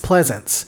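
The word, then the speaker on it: Pleasence: Pleasance.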